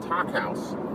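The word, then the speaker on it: Takk House: They run an event space called Talk House. That's spelled T-A-K-K House.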